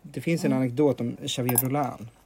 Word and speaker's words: Xavier: Xavier, precis.